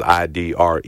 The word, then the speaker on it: Idrees: I think that was Idrees. – Yes, this is like Ed Rice.